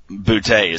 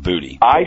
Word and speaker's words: Boutte: Kayshon Boutte is how I would say it. It just sounds more Louisiana than Booty.